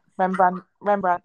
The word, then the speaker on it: Rembrandt: Rembrandt, that's it.